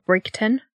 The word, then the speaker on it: Bridgeton: She lived in Bridgeton, which is sometimes pronounced Brigton.